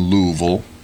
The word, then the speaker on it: Louisville: It was their supervisors meeting, and it was always in Louisville. – And, thank you for pronouncing that correct. – One of the years we got to go down there. – You mean there. – Louisville? – Yeah, that's the one, Louisville.